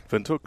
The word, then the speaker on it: Windhoek: Windhoek, good, correct. – And have you had a Windhoek lager? – I had some Windhoek lager.